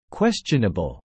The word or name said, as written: questionable